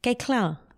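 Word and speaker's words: Koechlin: Kalki Kecklin. – Kekla. That's how she pronounces it.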